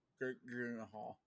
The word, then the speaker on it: Gyllenhaal: I think Jake Gyllenhaal, is that how you pronounce it? Yeah. I think he's. – No, you say it like this. Gyllenhaal.